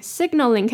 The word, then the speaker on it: signaling: signaling 可以分音节念，首先 sig 的 g 记得要发出 g 的音 sig， sig，再来 a 念圆的 o 的音 signal， signal，最后呢 ling， signaling， signaling， signaling that understanding their child self will be vital.